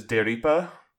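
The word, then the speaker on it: D-Reaper: I think one thing that I think is really interesting is the in Japanese, there's no, like, D sound. There's day instead. So the way they pronounce it is Deripa, which is, like, homophonous to deleter, and they even point that out at one point.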